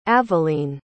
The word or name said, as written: aveline